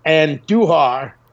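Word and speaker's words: Andújar: And the, the pr- proper pronunciation is Anduhar.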